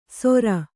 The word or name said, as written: ಸೊರ